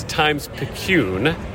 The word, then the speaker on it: Times-Picayune: Matt Paris from the New Orleans Times-Picayune.